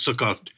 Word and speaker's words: Sukkot: I'm Ashkenazi, so it's Sukkos, and if I was, I think the other pronunciation is Sukkot, but it's a celebration of the harvest.